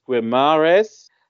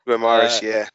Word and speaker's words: Guimarães: I think it's pronounced Guimarães. – Guimarães,